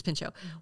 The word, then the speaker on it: Pinchot: I don't know how Bronson Pinchot pronounces his name, just to be clear, but, like, what does he have to gain from coming out? – I double-checked that one. Is Pinchot. – It's Pinchot.